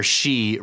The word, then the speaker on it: sidhe: We are now prepared to hear about the Dwan Maith, the good people, as the Irish call their she race. Now, she is interesting. It's spelled S-I-D-H-E, but it's pronounced she, okay? So when you hear of places like Glen She in Ireland, it's spelled S-H-E-E now, but Glen She means the Glen of the Fae, okay?